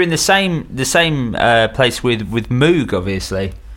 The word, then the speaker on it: Moog: Moog was supposed to rhyme with Vogue. That was where it came from. He was like, that's how you're supposed to pronounce it. – But the Mooga Fooga doesn't work as the Mooga Foga. – I know, but that's how they pronounce it. That's how he pronounced his own name.